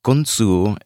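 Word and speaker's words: Grundsø: So hopefully I will do them proud, but it's Kunsu Explorer. And the way that it is spelled is it is spelled G-R-U-N-D-S-O-E-E-X-P-L-O-R-E-R. So like Grund So Explorer, but that's not how it's pronounced.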